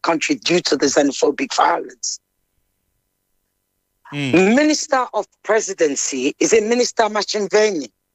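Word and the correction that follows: Ntshavheni: Did I pronounce it right? – Nchaveni.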